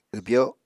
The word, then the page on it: wbyo: Apatani